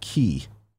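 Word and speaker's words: key (χ): The X in Kingdom Hearts Union X is pronounced key.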